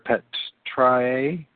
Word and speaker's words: Patriae: Lewin points out that Augustus was already being called the Pater Patriae, I guess as I pronounce it, it's P-A-T-R-I-A-E, Patriae, so the Pater Patriae.